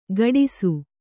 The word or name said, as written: ಕಾಡಿಸು